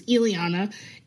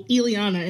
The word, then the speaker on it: Illyanna: It's a good thing that you asked me about my last name, because, you know, like, there's only one way to pronounce my first name, which is Illyanna, even though for some reason I will literally pronounce it for people, Illyanna, and they will instantly repeat Illyanna, and I'm like, no, like, did you not just hear what I said? I said Illyanna, but our last name, it is, you know, it's like a name from the colonizers.